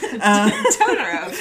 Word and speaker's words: Totoro: Totoro.